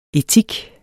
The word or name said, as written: etik